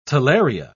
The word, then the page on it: talaria: British English